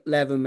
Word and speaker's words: Leven: I think I said Levin the other week to a to a Scotsman up there, and he said um, I was saying it wrong. I think it's, I think it's pronounced Leven, but I'm not too sure.